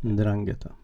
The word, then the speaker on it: Ndrangheta: Är det så man uttalar det? – Mm, ungefär. – Ungefär. – Ndrangheta.